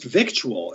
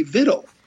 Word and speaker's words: victual: And I said, no, it's pronounced correctly vittle. And we went back to the Oxford English Dictionary, and sure enough, it's pronounced vittle. It comes from an old French word via the Latin, and it was pronounced vittle.